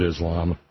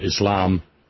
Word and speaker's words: Islam: None of that was done in the name of Islam. Or Islam, if you would care to correctly pronounce it, you cracker dumbass.